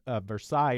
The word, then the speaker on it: Versailles: This is in Kentucky, V-E-R-S-A-I-L-L-E-S, Versailles in France. I assume Versailles. – Correct.